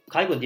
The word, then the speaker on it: Kygo: İlgi de azdı ama şimdi artık bunlarla birlikte Sarp'ın da söylediği gibi mesela Kygo diye okunuyor değil mi?